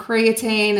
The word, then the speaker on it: creatine: Creatine, and is that how you say it? – Yeah, that's how you say it.